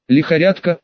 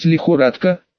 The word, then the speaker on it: лихорадка: Лихорядка, это что? Правильно говорить лихорадка, по слогам произнесла Лисанна, опередив Нацу.